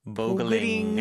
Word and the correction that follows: boiling: 보글링이라고 했는데, 네, boiling, boil, boil, boil, boil.